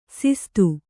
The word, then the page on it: ಸಿಸ್ತು: sistu